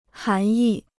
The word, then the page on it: 涵义: hán yì